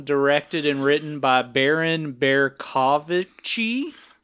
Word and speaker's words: Bercovichy: Because there's Vichy France, and that's when the Nazis were kind of holding the whole thing. So that's where I'm getting Vichy from. The rest, I think it's how you pronounce Berko. Bercovichy.